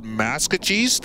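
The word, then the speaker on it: Maskwacis: And I did not know until like 2015, I think it was 16-17 season, that Hobima had been renamed, I believe it's called Maskeese. That's the proper pronunciation. I did not know that. – Was that the one that came up on our broadcast? – Yes, with the Musquesee, I think it's called.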